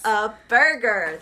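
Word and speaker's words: Whataburger: Well, you pronounce it Whata Burger, but, like. – I said it wrong. I failed. – No, you said it right. – You said it right, but, it took me forever to understand that it's supposed to be what a burger.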